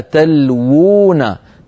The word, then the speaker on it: تلوون: الآية التي بعدها وهي الآية الأخيرة في الصفحة، إذ تصعدون ولا تلوون، تلوون فيها واوان، الواو الأولى موجودة في الخط والواو الثانية زادها علماؤنا بخط صغير حتى ينبه القارئ بأن النطق هنا بواوين، الواو الأولى مضمومة والواو الثانية ساكنة. ولا تلوون، ولا تلو، و الواو الأولى مضمومة والثانية مدية.